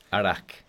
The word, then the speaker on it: arak: I know it's very hard for Western people to pronounce, but it's written as A-R-A-K. It's written as Arak. – Arak. – Yeah, but it's pronounced as Arak.